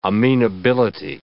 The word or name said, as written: amenability